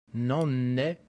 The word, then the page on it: nonne: Latin